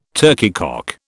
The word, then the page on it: turkeycock: British English